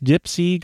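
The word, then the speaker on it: DIYpsi: Also, the D-I-Ipsy, pronounced Dipsy, going on at the Corner Brewery in Ypsilanti this Saturday and Sunday.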